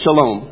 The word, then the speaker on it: shalom: In the Old Testament, I looked it up in this Isaiah passage, and this is transliterated how you would spell it, but I think that we all know it's pronounced shalom, right?